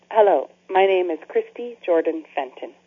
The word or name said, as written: Christy Jordan-Fenton